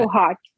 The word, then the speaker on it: Kohat: Kohat, Kohat, Kohat.